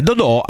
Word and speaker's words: Dodò: Ricordo per esempio aspettate perché poi bisogna vedere l'accento dov'è, perché Dodò ce l'ha della Fiorentina, ce l'ha ce l'ha l'accento, no, anche quest'altro Dodò. Dodò andò dalla Roma all'Inter,